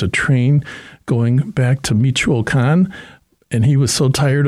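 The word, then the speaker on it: Michoacán: And my grandparents themselves ended up in Michigan because they're originally from Michoacan, which to the a lot of the Mexican people pronounce Michigan like Michigan and Michoacan, so it has the same hard CH sound.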